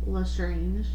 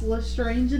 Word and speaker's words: Lestrange: So this literally says, although it is pronounced Lestrange in the films, the Scholastic official website states it is pronounced Lestrange.